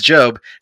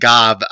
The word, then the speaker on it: Gob: It probably is Job. Having said that, after 16 plus years of calling it Gob, you'll forgive me if I can continue to fall back into that pronunciation of it.